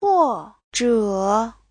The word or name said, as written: huo4zhe3